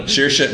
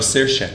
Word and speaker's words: Saoirse: Saoirse, no, Saoirse.